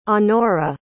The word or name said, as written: anora